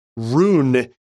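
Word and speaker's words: Roon: The premium solution, especially for audiophile types with lossless music collections, is the Rune ecosystem. I will spell that. That's R-O-O-N.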